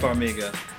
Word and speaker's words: Farmiga: So Kate Coleman, played by Vera, is it Vera Farmiga? Is that how you say it? – Farmiga.